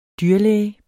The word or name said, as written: dyrlæge